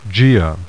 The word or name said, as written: Gaea